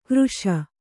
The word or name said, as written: ಕಾರೀಷ